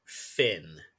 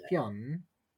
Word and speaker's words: Fionn: Finn is actually the anglicized version of it. So well done for making the connection. We pronounce it Fionn. So you Fionn. I think you pronounce it Fionn. So close enough. Yeah, Fionn. But in English, yeah, it's Finn.